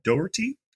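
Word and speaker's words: Dougherty: It was directed by Michael Doherty. I think I said that right. It's like Doe, E-R-T-Y.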